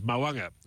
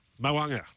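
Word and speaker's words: Mo'unga: He organised that interview with Glenn Climo with Very Elegant, a big tick there, and he tells me the pronunciation, and he should know he's a Kiwi, is Mawunga.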